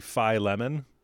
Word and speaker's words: Philemon: Philemon is how I was always trained to pronounce it by the British-trained teachers that I had.